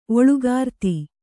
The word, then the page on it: ಓಲುಗಾರ್ತಿ: ōlugarti